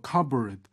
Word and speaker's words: cupboard: 그리고 D번 보면 One of the people is cleaning the surface of a cupboard. Cupboard라고 읽어요. 이거 발음 주의하셔야 됩니다. C-U-P 할 때 그 P가 발음이 안 돼요. Cupboard, 알았죠?